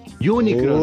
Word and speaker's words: Unicron: Oye, hay que decir que yo creo que aquí voy a unir fuerzas entre todos, que también una mención mega honrosa, Omicron, po weón. – Omicron. Unicron. Oh, Unicron, puta. siempre, eh, también, siempre, siempre lo digo mal, weón.